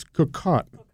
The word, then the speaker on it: Cocotte: How do you pronounce this? – It's Is it Cocotte? – It's Cocotte. – Cocotte. – Cocotte. Yep. You just have to kind of stick your jaw out a little bit when you say it. Cocotte.